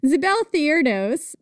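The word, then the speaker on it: Zibelthiurdos: Anyway, Zibeltheirdos, it's my podcast, that's how you pronounce it.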